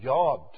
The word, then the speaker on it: yod: The smallest Hebrew letter in the Hebrew language is pronounced Jod. Although here in our English translation it's read jot, but in the Hebrew it's pronounced yod.